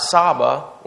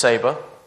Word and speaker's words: Saba: Not Saba, which is how I pronounced it for like 4 months leading up to this. My bad on that one. It is Saba.